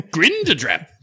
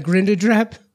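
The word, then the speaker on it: Grindadráp: Is that how they pronounce it there? – Grindadrap. – Looks like Grindadrap. – Or grind. Grindadrap. I immediately went with like Grindelwald.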